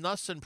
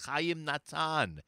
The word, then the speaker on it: Natan: Chaim Nussen pronounced his name with an S, and Avshalom Kor corrected him, saying him that he should say it how our ancestors pronounced it, Chaim Natan.